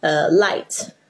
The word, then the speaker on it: light: 哦，就是有时候就是一个自然发音的结果，什么有时候很多 GH 是不发音，比如说像 light 或者是 fight， night，然后你就发现这 IGHT 呢 IGH 那个 GH 里面都没有发音，所以你就可以这样子去记忆这单词，你就会发现哎，好多字其实都已经背过它的一部分的东西了。